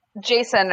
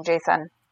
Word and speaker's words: Jacin: Jason, or that's how I say his name, Jason. – That's how Marissa says it, too. I just, as I told Marissa, it was Jason in my head for 3 years.